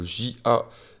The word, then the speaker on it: J.A.: Jashumon étant une pièce de théâtre japonaise bien perchée avec euh, à la production musicale l'incroyable J.A. A J.A. J point A comme des initiales et Caesar. Alors là, on l'écrit S-E-A-Z-E-R, mais il s'écrit aussi avec un C il s'écrit C-E-A-Z-E-R, il s'écrit C-A-E-Z-E-R.